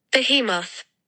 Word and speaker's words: behemoth: I think it's wrong. It should be. – Behemoth.